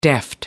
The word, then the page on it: deft: American English